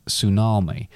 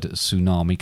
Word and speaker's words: tsunami: I wonder where the word tsunami comes from. – It's got a silent T.